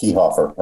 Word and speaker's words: Kefauver: Is it Kefauver or Kefauver? – It's pronounced Kehoffer, right.